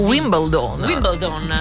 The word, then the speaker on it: Wimbledon: sua potential su Radio Number One, donne con la racchetta, perché parliamo di Wimbledon, perché si dovrebbe pronunciare così. – Wimbledon. Sì, bisogna invertire le lettere. – Esatto, la L è invertita, quindi in inglese è perfect, proprio nel British.